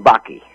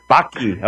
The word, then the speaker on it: Bakke: It's Norwegian Bakke. – Bakke. Okay, Bakke, just as my engineer told me, and then when I said it, I mispronounced it.